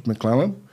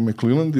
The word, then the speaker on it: McClellan: i general George McClellan. A, njega smo već a, e, pominjali s tim da sam ja nepravilno izgovorio to i rekao McClellan jer sam mislio da ima D na kraju, ali nema.